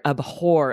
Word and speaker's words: abhor: It's kind of a weird word too, because we are kind of pronouncing the H, aren't we? – Yes, we do have to, right? You hit that H kind of hard, abhor.